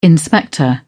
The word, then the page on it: inspector: British English